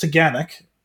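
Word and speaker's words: Taughannock: It's pronounced Tagannock. It doesn't necessarily 100% phonetically look like that.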